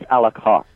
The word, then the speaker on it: Alacoque: Only to be pointed out that it's not Alacoque, it's Alacoque. – Yes, because that's how you pronounce it in French.